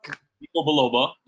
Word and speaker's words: ginkgo biloba: Ginkgo biloba.